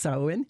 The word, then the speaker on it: Samhain: That's spelled Samhain, but it's pronounced Samhain.